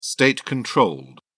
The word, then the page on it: state-controlled: British English